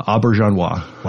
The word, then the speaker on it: Auberjonois: Uh, Auberjonois.